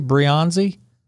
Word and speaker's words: Breyanzi: Breyanzi? – Perfect.